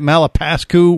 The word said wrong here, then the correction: Malapascua: How do you say it? – Malapascua.